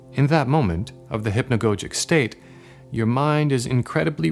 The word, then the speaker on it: hypnagogic: That's spelled H-Y-P-N-A-G-O-G-I-C, hypnagogic.